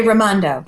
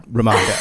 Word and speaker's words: Raimondo: is it Raimondo? Is that how you pronounce her name? – In Rhode Island, we say Raimondo. – Raimondo, okay.